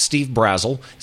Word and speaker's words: Steve Brazel: It's just at Steve Brazel, spelled the same as the country Brazil, but two L's.